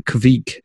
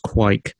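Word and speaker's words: Kveik: And the beer itself is, I think this is pronounced a Quake IPA. It's that funky Norwegian farmhouse yeast. That it's like the Kvik, it looks like, but I've heard it's pronounced Quake. It's a soft,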